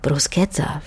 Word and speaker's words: Bruschetta: Und ehe man zudem solche Sachen wie das mit dem C und dem H, das wie ein K ausgesprochen wird im Italienischen, wie eben in Crucci oder Bruschetta, verstanden hat, kann es ja auch mal ein paar graue Haare lang dauern.